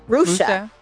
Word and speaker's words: Rushia: Rushia Brown? – Rusha. – Rusha. – Yeah, don't say, because I said it wrong a few times with her, and she gave me the dagger look like Rusha.